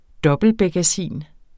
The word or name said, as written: dobbeltbekkasin